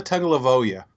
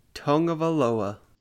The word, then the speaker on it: Tagovailoa: Tua Tungavailoa. Is that right? – No, that's not right, but good try. – Tua, that's his name. So Tua Tungavailoa, how do you say it? – Tua Tungavailoa.